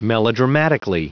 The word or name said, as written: melodramatically